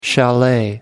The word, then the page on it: chalet: American English